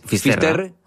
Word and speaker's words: Finisterre: Entonces, llegó a Finisterre. ¿Se dice así? – Finisterre. – Es como se ponen los gallegos también, en fin.